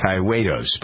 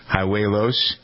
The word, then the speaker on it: Hijuelos: I believe his name is pronounced Hiuelos, but I'm not sure. Hiuelos. H-I-J-U-E-L-O-S.